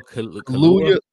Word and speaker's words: Kaluuya: Daniel Kaluuya.